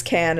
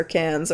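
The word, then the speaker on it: Cannes: But also, and also, Haley knows that it's Can and not Canes or Cans or whatever you said. – Con? I thought it was Con. – I've heard different pronunciations from different film professors who I thought it was Con because it sounded fancier. Valued. – I've heard Con and I've heard Can, but I know the S is silent.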